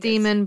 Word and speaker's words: daemon: and also what your daemon is. Mm-hmm. Is it daemon or demon? I know it's spelled with an A. – It's demon, but you know, it's got a funky demon letter in it, so it's not demon with a funky specific letter.